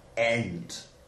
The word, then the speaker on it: ant: That's that's just the way they would pronounce the word ant. Ant, mate. Yeah, an ant. Ent. No. Ent. – Like end, but with a T instead of a D.